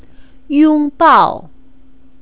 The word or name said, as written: yongbao